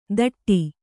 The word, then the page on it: ತಟ್ಟಿ: taṭṭi